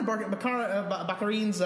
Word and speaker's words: Baccarin: I wonder what Marina Baccarin's, that's how it's pronounced.